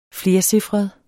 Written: flercifret

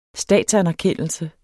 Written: statsanerkendelse

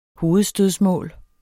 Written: hovedstødsmål

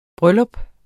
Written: bryllup

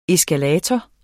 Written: eskalator

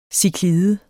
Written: ciklide

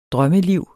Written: drømmeliv

